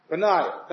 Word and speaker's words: Peninnah: Peninnah, right? Peninnah, thank you.